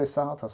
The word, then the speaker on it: Sartre: He'd left her a lot of brilliant books, like Hemingway's, Camus, Sartre's. Do you say Sartre? – Sartre, yes.